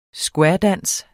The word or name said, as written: squaredance